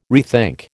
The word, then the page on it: rethink: American English